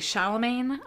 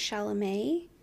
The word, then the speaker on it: Chalamet: And then I know Timothy Chalamet, I believe is how you pronounce his name. – I think it's Chalamet.